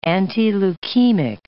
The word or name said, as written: antileukemic